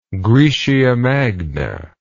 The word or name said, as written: graecia magna